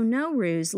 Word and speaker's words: Nowruz: Like, the Persian word N-O-W pronounced no means new. And the word R-U-Z, ruz, means day. So Nowruz literally means starting a new day.